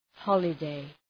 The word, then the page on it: holiday: ‘hɒlə,deı